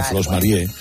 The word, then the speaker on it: Flos Mariae: Flos Mariae. – Mariae. Ya es distinto. Si dice Mariae, ya. – Bueno, pues se pronuncia Flos Marie.